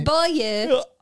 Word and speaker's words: buoy: There's only one way to pronounce this word. – Okay, tell me. – Buoy. – What is wrong with me? – Buoy.